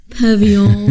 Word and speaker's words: pavillon: I was going to say you pronounce it wrong. It's pavillon. – It's pavillon. In France, it's pavillon.